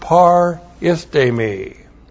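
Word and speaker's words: paristemi (παρίστημι): And then the verb paristemi. That's P-A-R-I-S-T. Long E-M-I. Paristemi.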